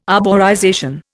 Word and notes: arborization: Indian

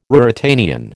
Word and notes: Ruritanian: American English

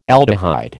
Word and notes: aldehyde: American English

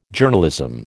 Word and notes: Journalism: American English

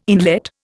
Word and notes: inlet: Indian

